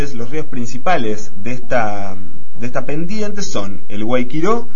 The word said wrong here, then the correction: Guayquiraró: Sí. – Perdón, el Guayquiraró es el nombre. – Sí, sacaste una sílaba, vos querías hablar menos, es eso.